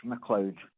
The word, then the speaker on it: McLeod: Mr. Scott George McLeod. – George? – Yes. – Yeah. – And McLeod is spelled M small c l e o d.